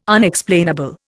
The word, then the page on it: Unexplainable: Indian